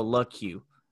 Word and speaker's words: LaCroix: I think it's LaCroix because it says on Basketball Reference it's LUH and then Q is like in caps. So LaCroix. Jaden LaCroix.